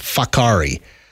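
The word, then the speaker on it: Whakaari: And no, sure enough, it's W-H-A-K-A-A-R-I, pronounced Fakari.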